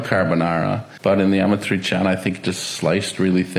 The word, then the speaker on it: amatriciana: Next, we have amatriciana. Did I pronounce it right? – You did a pretty good job. Yeah. That's 99% almost there.